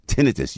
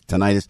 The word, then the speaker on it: tinnitus: Tinnitus. You think it's tinnitus, properly pronounced tinnitus.